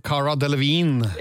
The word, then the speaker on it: Cara Delevingne: Ja, vi må dit, og det starter med en eh, som merger de to verdenene til gangs, og nå må du muligens hjelpe meg litt med uttale på navn her, for skuespillerinne og modell Cara Delevingne Young? Ja, Si noe sånt, det er jo Car Cara Delevingne.